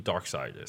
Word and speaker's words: Darkseid: I'm like, this is, and I'm like, it's pronounced S-I-D-E, like, because it's spelled S-E-I-D. – Mm. – Like, so it's like Darkseid.